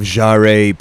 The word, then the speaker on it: Jaret: Welcome back to the Soul of Jaret podcast. It's not really how you say my name, but you can say it that way if you want to.